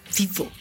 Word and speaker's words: vivo: Y se le llama vivo. vivo. Así, su pronunciación es muy vivo.